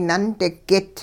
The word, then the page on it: nundigit: hupa